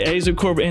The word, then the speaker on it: Aza Corbett: We have Kayla Houston and we have Aza Corbett. Am I saying that correctly? Yeah. Okay, Aza Corbett.